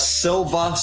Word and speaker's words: Silva: If you want to know more about the movie, go to The Silva Screen, not The Silver Screen.